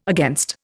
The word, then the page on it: against: Indian